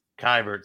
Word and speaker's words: Keibert: Yeah, it's Mr. Kelbert Ruiz. I said his name right. Kybert. Sorry.